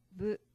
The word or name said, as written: bu